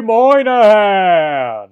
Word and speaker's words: Moynihan: Bobby Moynihan!